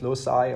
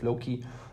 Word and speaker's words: loci: In scientific papers, it's often called method of loci or method of Loki, depending on how you pronounce it.